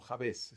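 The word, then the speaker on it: Jabés: Un libro que quizás reveló lo que hay en el corazón de las personas fue La oración de Jabes, o Jabes, está escrito con acento en la E.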